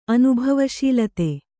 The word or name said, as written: ಅನುಭವಶೀಲತೆ